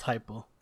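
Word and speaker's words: Heupel: Uh, but look what look what Hupel took over there. Hypel, whatever you say his name. It's pronounced Hypel. – Hypel, I believe you're right. Uh, uh yeah, Hypel. That sounds right.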